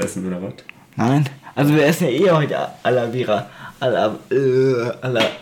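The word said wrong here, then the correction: Arrabbiata: er kann die Nudel, er kann das Nudelgericht nicht aussprechen. Al, al Arabiata, ja.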